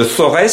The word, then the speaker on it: Forez: Alors, votre association porte le nom de Forez, ou de Forez si on prononce bien, c'est vers Saint-Étienne.